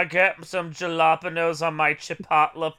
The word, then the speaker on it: Chipotle: And I'm like, I know I said it wrong. It's Chipotle. But no, it's just fun.